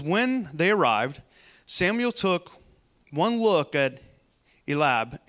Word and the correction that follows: Eliab: and thought, Elab, Eliab. How do you say that? Elab, Eliab. Eliab. That's That sounds a lot better. Eliab, yes.